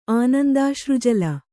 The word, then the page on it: ಆನಂದಾಶ್ರುಜಲ: Kannada